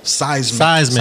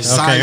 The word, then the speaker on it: Seismique: Seismic. – Okay, Seismic. Everybody go check it out. – It's spelled Seismique.